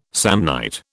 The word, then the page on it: Samnite: British English